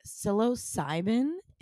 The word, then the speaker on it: psilocybin: I've been trying microdosing with psilocybin is technically the chemical. – Ooh, good pronunciation, right? As well. – Right? I killed that.